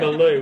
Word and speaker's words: Baloo: Baloo. Baloo.